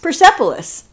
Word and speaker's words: Persepolis: Persepolis.